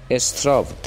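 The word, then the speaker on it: Straw: او حتی در روایت ماجراهای سفر پرحاشیه‌اش به ایران اشاره می کند که نام او در زبان فارسی استراو تلفظ می شود و نه آنطور که در زبان مبدأ است.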